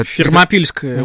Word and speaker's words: Фермопильское: И на самом деле, ну, все помнят историю про термофилы, там когда... – Фермопильское ущелье. – Вот, по-сербски да. Это немножко по-другому произносится, простите, я только что прилетел.